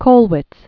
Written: Kathe Kollwitz

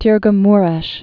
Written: Tirgu-Mures

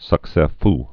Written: succes fou

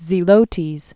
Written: Simon the Canaanite

